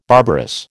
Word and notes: barbarous: American English

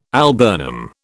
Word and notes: alburnum: British English

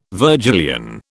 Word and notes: Virgilian: British English

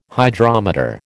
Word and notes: Hydrometer: American English

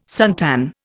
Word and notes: suntan: Indian

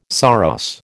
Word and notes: saros: American English

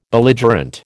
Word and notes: Belligerent: American English

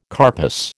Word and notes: carpus: American English

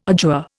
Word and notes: adjure: Indian

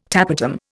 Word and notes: tapetum: Indian

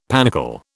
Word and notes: panicle: British English

